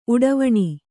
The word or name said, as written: ಉಡಿವಣಿ